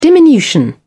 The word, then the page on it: diminution: British English